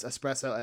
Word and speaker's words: espresso: It could play on that whole, like, you could play on that whole idea that some people pronounce espresso as expresso.